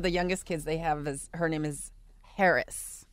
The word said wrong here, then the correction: Heiress: Harris? – H-E-I-R-E-S-S. – Mm. Oh, like heiress? – Heiress. – Like she's an heiress. – I said it incorrectly.